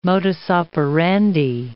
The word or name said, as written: modus operandi